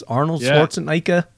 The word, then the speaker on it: Arnold Schwarzenegger: With Jean-Claude Van Damme, you know, Steven Seagal, Bruce Willis, Arnold Schwarzenegger.